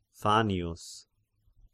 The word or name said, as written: Fannius